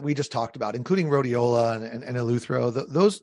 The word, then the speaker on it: rhodiola: robus, uh, rhodiola rhodiola rhodiola yeah.